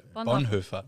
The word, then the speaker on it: Bonhoeffer: Like, I'm trying to get an understanding of what you do in Germany, because all I can think of is Dietrich Bonhoeffer. You know, how do you say it? – Very good. Bonhoeffer. – Bonhoeffer, yeah. – Almost. Yeah, lovely.